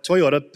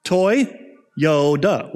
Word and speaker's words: Toyota: I was driving on the road, and I was looking at a, at a Toyota pickup truck, and I was saying to myself, Toyota, Toyota, Toyota, and I realized I pronounced the Y twice, toy Yoda.